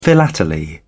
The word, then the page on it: philately: British English